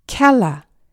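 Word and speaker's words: cella: 이 좁은 공간에 숨기다, 감추다 라는 뜻의 인유어 켈이 라틴어에서는 켈라, C-E-L-L-A가 e 되는데요. 기억하시겠지만 라틴어에서 철자 C는 언제나 K 사운드로 발음되었죠. 따라서 C-E-L-L-A는 e 켈라라고 발음되었습니다.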